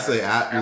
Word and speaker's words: Appalachian: Or how do you say it? Appalachian? – Appalachian. Look, I'm from Atlanta. I say Appalachian.